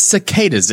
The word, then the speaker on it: cicadas: It's cicadas. What do you mean choosing? There's no choosing. That's just how it's pronounced.